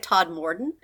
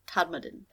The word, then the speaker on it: Todmorden: I had to listen to pronunciations because it looks like Todmorden. – It does. – But when I listened to people pronounce it online, the person that actually had a British accent, he pronounced it more Todmorden.